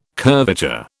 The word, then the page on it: curvature: British English